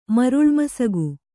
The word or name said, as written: ಮರುಳ್ಮಸಗು